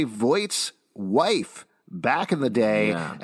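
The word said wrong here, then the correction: Vought: You said Voight. – Voight. I've been pronouncing it wrong. Vaught.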